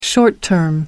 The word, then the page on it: short-term: American English